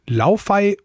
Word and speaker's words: Laufey: Die wird Laufey geschrieben, aber eben Laufey ausgesprochen.